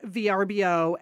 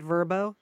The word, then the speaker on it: Vrbo: And can I just say, this is a total aside, I've noticed that VRBO is now running ads and they want us to pronounce it Vrbo. I'm just not going to do that. – No. – That's not, no. It's not going to happen here. I've always called it VRBO.